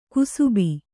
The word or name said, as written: ಕಸಬಾ